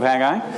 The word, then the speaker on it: Haggai: And the correct answer is Haggai. When you pronounce it in Hebrew, it's Haggai. Two syllables, that's it.